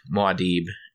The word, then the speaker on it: Muad'Dib: I call it Muad'Dib. – Muad'Dib. I say Muad'Dib. Okay. – And Muad'Dib. So, Muad'Dib? That's better. – That's Muad'Dib.